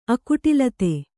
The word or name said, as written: ಅಕುಟಿಲತೆ